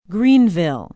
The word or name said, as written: greenville